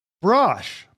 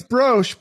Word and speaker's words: Brosh: Nili Brosh. But yes. It looks like it's Brosh, but everybody says Brosh. So I'm like, either everyone's saying it right or wrong, or...